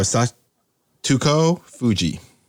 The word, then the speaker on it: Satoko Fuji: Piano, Robert Glasper, or Satoko Fuji. – Who's the second? – Satoko? Satoko. Am I saying it wrong? – Satoko Fuji. – Yeah. – Is it right? – Yes, I believe that's right.